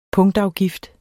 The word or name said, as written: punktafgift